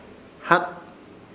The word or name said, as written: Hat